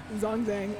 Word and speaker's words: Zhongzheng: Also known as Zhongzheng, as I've been pronouncing it incorrectly for the last 6 months.